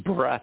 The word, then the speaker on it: Bratt: My first name is Ian, which is spelled I-A-I-N from the Gaelic Scottish roots. And my last name is Bratt, B-R-A-T-T. That's Bratt, B-R-A-T-T.